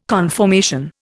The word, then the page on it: conformation: Indian